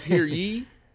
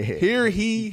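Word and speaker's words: Hear ye: It's here ye. Here ye. – I think it's ye. – It's Y-E. Definitely 100% ye. – Yeah, right. – Yeah. Here he. – You're saying it wrong.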